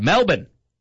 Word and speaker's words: Melbourne: No, it's not. You don't pronounce the R. Melbourne. I'm pretty sure. Ask an Australian.